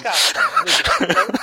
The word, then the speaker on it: Capcom: Potevi dire semplicemente Tatsunoko vs no, Capcom. Capcom. che l'avevo detto benissimo.